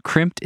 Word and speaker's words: Crimpd: Crimped is spelled C-R-I-M-P-D. That's crimp with a D at the end.